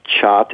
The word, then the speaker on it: khat: Well, the drug is called Khat. Some people pronounce it chat. It's spelled K-H-A-T, and it's a lot like the coca leaves, which most of us have heard about, that cocaine is made from.